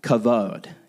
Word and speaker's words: kavod: I know there's a B, but it's pronounced kavod, okay?